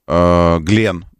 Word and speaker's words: Glenn: Согласно трансляциям сайтов и Space, мегаракета New Glenn, Glenn, правильно говорить, ну, в честь астронавта.